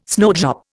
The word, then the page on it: snowdrop: Indian